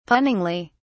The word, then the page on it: punningly: British English